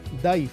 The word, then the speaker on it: Dàimh: Se escribe así, Daim con MH, pero se pronuncia Daif en gaélico.